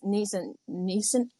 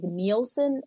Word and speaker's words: Neeson: Jerry getting Liam Neeson. I always want to say it like Nielsen, and I'm like, I don't think that's right. Because Nielsen's is something else.